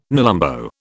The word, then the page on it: nelumbo: British English